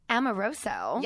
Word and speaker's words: Amoroso: Miss Alyssa Amoroso.